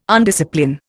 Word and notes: undiscipline: Indian

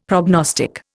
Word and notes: prognostic: Indian

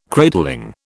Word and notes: cradling: British English

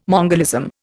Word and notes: mongolism: Indian

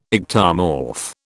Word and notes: ectomorph: British English

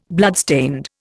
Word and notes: bloodstained: Indian

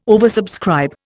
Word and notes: oversubscribe: Indian

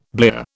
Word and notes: blear: British English

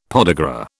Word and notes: podagra: British English

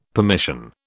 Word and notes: permission: British English

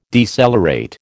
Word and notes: decelerate: American English

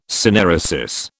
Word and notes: synaeresis: British English

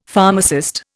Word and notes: pharmacist: Indian

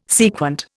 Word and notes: sequent: Indian

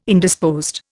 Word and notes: Indisposed: Indian

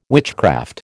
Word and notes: witchcraft: American English

